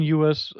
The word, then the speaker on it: Ewers: Ist es Ewers? Ist es, ja, Quinn Ewers, so ja. Wird es im Amerikanischen dann ausgesprochen.